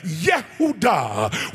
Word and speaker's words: Yehudah: That means uh, that the people who have determined in their heart uh, Yehudah, which is the full pronunciation of the name Judah, which means uh, let God be praised.